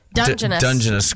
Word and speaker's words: Dungeness: Dungeness crabs.